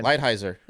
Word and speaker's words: Lighthizer: Lighthizer.